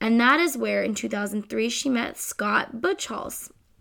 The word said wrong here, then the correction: Buchholz: Buckholz, I think that's pronounced. – Whoops. Scott Buckholz.